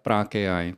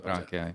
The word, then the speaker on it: PRG AI: Prák AI. – Prák AI. – My jsme vlastně zkratkou, ale ten název se vyslovuje Prák AI.